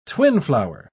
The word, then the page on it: twinflower: British English